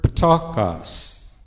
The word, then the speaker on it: ptochos: It's the word ptokos. P-T-O-C-H-O-S. Ptokos. Kind of sounds like you should spit after you say it.